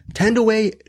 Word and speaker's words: Thandiwe: And then we're also doing a movie with Tandaway Newton, not Tandy Newton. She pronounces her name now.